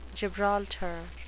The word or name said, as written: gibraltar